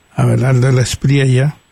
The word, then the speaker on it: Abelardo de la Espriella: Y esperemos, Alejandro, que ciertamente no alcance este abogado de 47 años, Abelardo de la Espriella, nunca lo pronuncio de una sola forma, pero realmente es Priella, Abelardo de la Espriella, alcance la presidencia de Colombia, porque sería una desgracia para su pueblo.